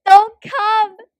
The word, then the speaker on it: Duncomb: And then this alley called Dun Cum. I don't know how to say it. – Great name. Oh my god. D-U-N-C-O-M-B. – Say the word. Say the word. Don't come.